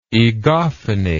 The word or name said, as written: egophonic